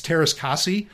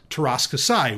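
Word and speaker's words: Teräs Käsi: And she says, it's Taras Kasi. Which Taras Kasi, I thought, was pronounced and has been pronounced by a lot of people for many years, Taras Kasai,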